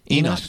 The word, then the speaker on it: Enosh: Enosh? Did I say that right? – Enosh.